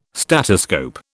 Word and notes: statoscope: British English

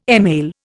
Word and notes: email: Indian

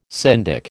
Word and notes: syndic: American English

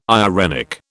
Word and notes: irenic: British English

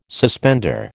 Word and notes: suspender: American English

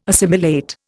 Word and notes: assimilate: Indian

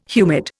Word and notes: humid: Indian

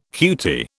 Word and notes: cutie: British English

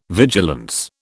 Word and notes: vigilance: British English

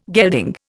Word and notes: gelding: Indian

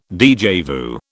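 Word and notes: déjà vu: British English

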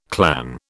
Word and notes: clan: British English

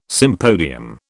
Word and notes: sympodium: British English

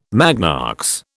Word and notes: magnox: British English

